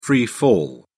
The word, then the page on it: free fall: British English